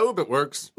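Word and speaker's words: Ub Iwerks: I want to see if I'm pronouncing this guy's name right. Oob It Works. Is that right? – Yeah, Oob It Works. – Oob. – Oob It Works? – Oob is his first name, U-B.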